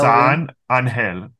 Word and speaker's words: San Angel: San Angel. Yeah, that would be how you pronounce it in Spanish.